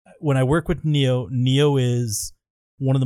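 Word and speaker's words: Niyo: When I thought it was John Nio. Is it Neo? – Neo. – I've been saying it wrong this whole time.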